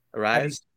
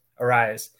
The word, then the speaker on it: Arraez: Arraez. – Arraez.